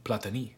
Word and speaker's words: Platini: As a player, I should say, the proper French pronunciation, Platini.